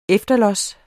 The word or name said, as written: efterlods